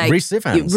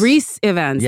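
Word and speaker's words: Rhys Ifans: Oh, Rhys Ivans. – Rhys Ivans, yeah.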